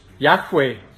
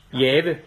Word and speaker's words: Yahweh: For når Aaron siger Herren, ja, så bruger han faktisk det navn, der er gengivet som YHWH på hebraisk, og som de fleste lærte i dag mener skal udtales Yahweh eller Jave, som vi siger på dansk.